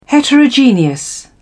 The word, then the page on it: heterogeneous: British English